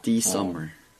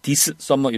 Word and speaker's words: this summer: SS가 충돌할 때는 앞에 S를 날려주는 겁니다. – 네. – 그래서 this summer 이러지 마시고 this summer 이렇게 발음하시는 거예요.